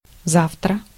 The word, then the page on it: tomorrow: /tə.ˈmɑɹ.oʊ/; [tə.ˈmɑɹ.oʊ]; [tə.ˈmɒɹ.əʊ]